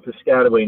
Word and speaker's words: Piscataway: That was in Piscataway, New Jersey. – Yes. Yep. I went to high school and middle school in Piscataway, New Jersey.